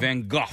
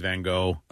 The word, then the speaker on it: Van Gogh: Not Van Gogh, but Van Gogh. – Yeah, the American pronunciation is Van Gogh. – Uh-huh. – But if you're Dutch or French, you can say it with a How does he say it? – Van Gogh.